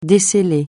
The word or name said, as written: desceller